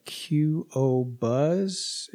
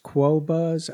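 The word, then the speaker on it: Qobuz: This Tidal I haven't used, and Qo Buzz, or is it pronounced Quo Buzz? I don't know. – That's one. I just say Qo Buzz and keep on, but it's probably Quo Buzz.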